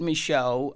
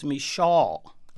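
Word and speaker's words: Micheaux: Michel, is that how you say his name? – Well, there, you know, I've said Michel, but some people say that it was sometimes Michal.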